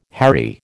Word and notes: harry: American English

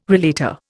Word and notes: relater: Indian

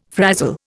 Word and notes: frazzle: Indian

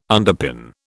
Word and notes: underpin: British English

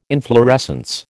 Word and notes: inflorescence: American English

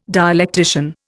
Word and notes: dialectician: Indian

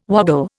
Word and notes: woggle: Indian

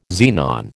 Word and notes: xenon: American English

